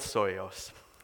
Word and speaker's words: Psihoyos: I even looked up the pronunciation. Psoios is what I'm going to go with. Psihoyos. It's a tricky one. There wasn't a definitive answer online when I looked up the pronunciation. So we're going to go with Soyos.